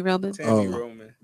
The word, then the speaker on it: Roman: Let's get into this Tammy Romaine topic that Mara has been waiting for patiently. You guys know who, did I say her name right? – Tammy Roman.